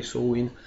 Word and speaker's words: Samhain: la pronuncia Samain rispetto alla pesantezza di Sowin.